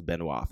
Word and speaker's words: Benoit: Eh, den är från juni 2011 och är skriven av en kille som heter Benoit Denisette Lewis jag tror att det uttalas Benoit, för det stavas B-E-N-O-I-T. – Mm. Det låter korrekt.